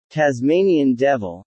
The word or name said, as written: Tasmanian devil